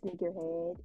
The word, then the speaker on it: SniqkerHead: Oh yeah, so you can contact me on my Instagram, SniqkerHead. It's spelled SNIQ. Okay, Nitty, not SNIC. Okay. – Get it right. Neek. – SniqkerHead. SniqkerHead. I have a TikTok. It's official SniqkerHead.